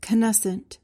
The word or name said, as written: canescent